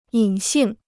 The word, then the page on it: 隐性: yǐn xìng